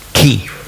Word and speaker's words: Chi: Chi, das hat man eigentlich ausgesprochen Ki. Nicht Schweizerdeutsch, Chi. Also nicht Chuchichastli. In dieser Art, sondern in Ki.